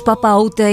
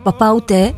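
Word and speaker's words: Papaoutai: Ο τίτλος Παπα Ούτε είναι ένα λογοπαίγνιο που προφέρεται ακριβώς όπως η γαλλική ερώτηση Παπα Ούτε.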